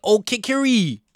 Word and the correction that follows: Okereke: Okereke with an interception.